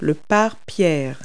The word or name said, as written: canopy